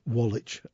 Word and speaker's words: Wallach: And I think it's pronounced Wallach, not Wallach. That's my Northern English pronunciation. W-A-L-L-A-C-H.